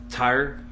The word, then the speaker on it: Tyr: But I guess Wikipedia says it is Tyr, T-Y-R.